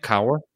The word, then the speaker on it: Kaur: It's Rupi Kaur, it's the last name is spelled K-A-U-R, Jason. – She's a really wonderful poet. – How do you pronounce her name? – Kaur, I think. Kaur. – Kaur. Yeah. That sounds right.